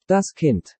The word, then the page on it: Das Kind: Deutsch